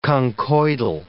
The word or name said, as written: conchoidal